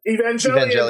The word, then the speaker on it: Evangelion: I never know how to pronounce that. – Evangelion. – Very good, yes.